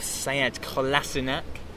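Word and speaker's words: Sead Kolašinac: He brought in a new signing in the form of Sead Kolasinac.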